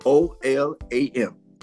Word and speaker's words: olam: The Hebrew word is O L A M, olam.